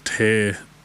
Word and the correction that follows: Tare: Duven. – Oh, Trey. Trey Dubin. – Trey? It was written here as Tear, so. – Right. – I don't know. T-A-R-E. – Yeah, Trey. Dubin.